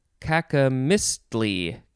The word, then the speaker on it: cacomistle: Next we have cacomistle, C-A-C-O-M-I-S-T-L-E. I guess you could also pronounce it cacomistly, cacomistly.